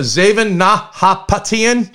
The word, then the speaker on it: Zaven Nahapetyan: We're talking to Zaven Nahapetyan.